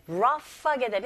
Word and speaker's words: rough: 네, rough하게 되면 여러분 GH로 끝나는 단어지만 소리는 rough. 이 GH가 f 발음이 납니다. 거친이란 뜻으로 발음하실 때 rough, rough 하고 끝을 윗니로 아랫입술을 살짝 긁어주면서 소리를 내시면 돼요.